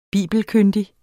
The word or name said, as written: bibelkyndig